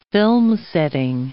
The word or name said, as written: filmsetting